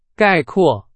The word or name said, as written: 概括